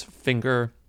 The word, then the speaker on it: Finger: my last name is Finger, spelled like the finger on a hand. It's very obvious, and people sometimes ask because they feel like it can't possibly be pronounced like finger, but it absolutely is.